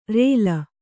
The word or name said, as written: ಱಳ